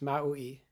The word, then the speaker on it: Maui: And it's probably pronounced with three syllables, Maui.